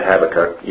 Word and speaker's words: Habakkuk: Psalms, Proverbs, Ecclesiastes, Song of Solomon, Isaiah, Jeremiah, Lamentations, Ezekiel, Daniel, Hosea, Joel, Amos, Obadiah, Jonah, Micah, Nahum, Habakkuk, and some pronounce that Habakkuk, either way is okay.